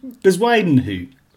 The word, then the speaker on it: Bezuidenhout: It's how do you pronounce Christian Bezuidenhout? Say that again. – Bezuidenhout. – Bezuidenhout. – Or something very similar to that. They do tend to mask it on the commentary as well, don't they? But yeah, as I understand it, it's Christian Bezuidenhout. – Is it? So it's not a silent T. It's not a silent T at the end, no? – But it might be, yeah.